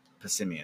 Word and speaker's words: Passimian: I caught a Passimian. I don't know how you say it. – Passimian.